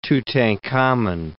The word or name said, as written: Tutankhamen